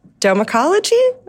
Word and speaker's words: domecology: You coined the term domecology? Am I saying it right? – Yes, that's correct. – Yay. – Think domicile.